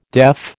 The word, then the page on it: deaf: American English